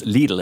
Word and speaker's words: Lidl: And just to nail it once and for all, apparently the correct pronunciation, and we've all been getting it wrong all this time, is Lidl. It rhymes with needle, not with piddle.